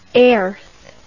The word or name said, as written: Airth